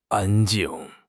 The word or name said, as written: an1 jing4